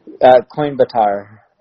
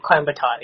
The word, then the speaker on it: Coimbatore: Coimbatore. – Coimbatore, yeah. That's Coimbatore, actually.